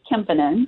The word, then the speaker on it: Kemppainen: It is Kemppainen, but that's okay.